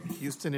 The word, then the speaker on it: Huston: Mr. Huston? Is that the way to correctly pronounce your name? – It's pronounced Houston, like the Houston. City, Your Honor. – Okay, Houston it is.